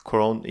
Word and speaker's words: Koron: and that's why I called my podcast Koron. Koron